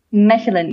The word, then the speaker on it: Mechelen: Mechelen, yes, Mechelen.